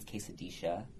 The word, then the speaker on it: quesadilla: Like, the double L is so different. It's like shh instead of, like, the Y sound. Like, instead of quesadilla, it's quesadisha.